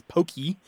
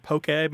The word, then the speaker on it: poke: And now the Hawaiian word we're talking about here, to go back to the fish dish, is P-O-K-E, pronounced basically as poke, although in the mouths of Anglophones, it usually comes out as pokey or poke. – Poke. – I have seen it have an accent on the E, but that is incorrect.